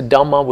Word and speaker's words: Dhamma: You know, this is a Dhamma with a long A at the end, not the Dhamma as in the Dhamma of the Buddha with a capital D, but the Dhamma as in phenomenon.